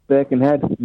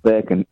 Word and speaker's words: Birkenhead: Anyway, it's Birkenhead, not Birken. You're pronouncing it as though it's Birkenstead. Down your way.